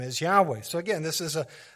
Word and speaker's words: Yahweh: It says several early Greek writers of the Christian church testify, where they witnessed, that the name was pronounced Yahweh.